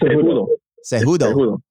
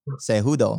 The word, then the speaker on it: Cejudo: It's Cejudo. – Cejudo.